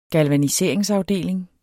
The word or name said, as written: galvaniseringsafdeling